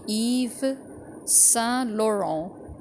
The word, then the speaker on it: Yves Saint Laurent: In English, the most authentic pronunciation would be Yves Saint Laurent. So the R will be pronounced, but everything else will be the same as the original pronunciation. So I'll repeat all these words again.